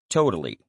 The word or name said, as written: totally